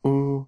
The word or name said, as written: u